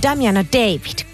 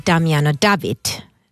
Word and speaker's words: Damiano David: Kun tulee aina sanottu Damiano David, kun hän on tietysti Damiano David. Tyler ja Nile Rodgers. Ihan oikein, kun laitoit sitä palautetta. Sitä aina haluaa suussaan vääntää enemmän Davidiksi.